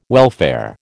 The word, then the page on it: welfare: American English